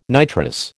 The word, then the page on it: nitrous: American English